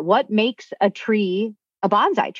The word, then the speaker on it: bonsai: So it's actually bonsai rather than bonsai. I mean, that's a common misunderstanding in the West.